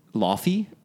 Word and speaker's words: Laufey: It's spelled L-A-U-F-E-Y. And I'm so tempted as an English speaker to say Laufey, but I watched YouTube videos and it's pronounced something more like Laufey. And I think even that's a little wrong, but that's closer to what how you say it.